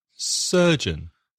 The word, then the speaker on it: surgeon: Surgeon. – Cirujano. – Surgeon. Spelling S-U-R-G-E-O-N. And the first vowel sound is the same as nurse, sir, sir, and jun, surgeon. Another profession, judge, J-U-D-G-E.